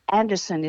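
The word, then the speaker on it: Andersen: Yeah, well, I guess I was born, the name Kiss is a Danish name, and Anderson is spelt with an S-E-N, it's Scandinavian.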